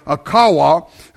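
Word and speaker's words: qavah: And it said it's from an old Hebrew word is pronounced a kawa,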